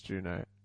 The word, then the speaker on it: Juneau: The city and borough of Juneau is pronounced Juno.